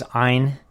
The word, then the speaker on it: Ayn: Actually, her name is Ayn. – Yes, that's the correct pronunciation, yes.